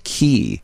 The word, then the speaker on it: quay: The word Q-U-A-Y, which I think is where the boats go sometimes, into a Q-U-A-Y is pronounced key. Well, I would have been K or Quay all day on that, but that's key.